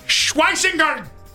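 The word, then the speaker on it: Schwesinger: And 94, I think this is Anthony Alant's favorite player, Carson Scheisweisinger. Linebacker out of UCLA. Well, I think it's Schweisinger. That's how you say his name.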